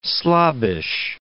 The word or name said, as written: slobbish